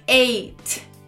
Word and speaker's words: eight: A gente tem um gh aí, mas esse GH é mudo. Olha que maravilha. Então você só tem que pronunciar eight. Eight.